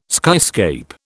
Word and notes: skyscape: British English

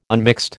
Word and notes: unmixed: American English

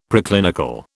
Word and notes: Preclinical: British English

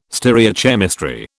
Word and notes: stereochemistry: British English

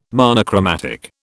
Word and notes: monochromatic: British English

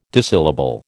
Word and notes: dissyllable: American English

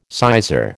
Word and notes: sizar: American English

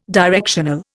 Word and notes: directional: Indian

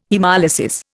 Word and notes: haemolysis: Indian

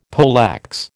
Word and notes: poleaxe: American English

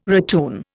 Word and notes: ratoon: Indian

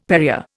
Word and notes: Perrier: Indian

